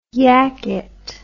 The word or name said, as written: yacket